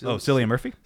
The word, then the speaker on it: Cillian Murphy: Cillian Murphy? – Cillian Murphy is in Oppenheimer.